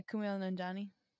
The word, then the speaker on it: Kumail Nanjiani: Kumail Nanjiani. Is that, did I say it right? – Yeah, Kumail Nanjiani.